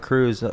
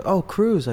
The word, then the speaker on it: Cruz: When I pronounce my name, Art Cruz, Cruz. I go, like Penelope, not Tom though.